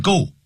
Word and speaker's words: goal: G-O-A-L 不要念成 goal 叫 goal